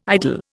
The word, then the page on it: idol: Indian